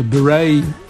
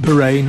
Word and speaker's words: beret: Prince, and of course a beret is pronounced beret, not beret.